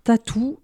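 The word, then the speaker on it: t.A.T.u.: Alors Tatou, ça s'écrit T-A-T-U, d'accord.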